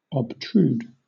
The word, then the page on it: obtrude: British English